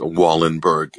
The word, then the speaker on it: Wallenberg: But definitely a spectacular family, the Wallenberg family. – Yeah, and that's pronounced Wallenberg if you're Anglo-sized. – Yes, of course, Wallenberg. Yeah, I'm going with the Swedish pronunciation out of habit.